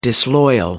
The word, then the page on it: disloyal: American English